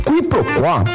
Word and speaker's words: quiproquó: Q-U-I-B-R-O-Q-U-O com acento agudo. Quiproquó, também informalmente pronunciada como quiproquó, é a ação de confundir uma coisa com outra, ou o desentendimento decorrente dessa confusão.